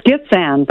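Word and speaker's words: Gitxsan: It's Gitxgan, but that's you were pretty close.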